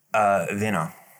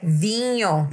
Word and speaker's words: vinho: But it's with an H. V-I-N-H-O. – That's not how you say it. You're spelling correctly. How do you say it? – Vino. – No. – Wino. – No. It's wiener. Thank you very much. Take a drink. It's vino. – It's what? – Vinho. – Vinho.